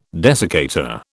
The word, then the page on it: desiccator: British English